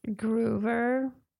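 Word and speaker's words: Grover: Och eh, Groover, uttalas det så kanske? – Groover.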